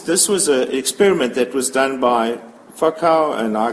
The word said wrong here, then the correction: Foucault: excuse my pronunciation, and Physio, Foucault and Physio.